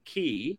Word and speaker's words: ghee: Ghee, which she actually pronounces it key, coconut water, squash, honey, those kind of things are water.